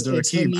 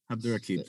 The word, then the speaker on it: Abdurraqib: Well, so last year, I guess your all-time favorite author, and I hope I don't butcher this, Hanif Abdurraqib. I can't say it. – It's Abdurraqib. I practice saying it. Abdurraqib, thank you.